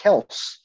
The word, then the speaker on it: Kelce: I hate to correct you, but he actually did an interview like a month ago where his actual last name is pronounced Kels.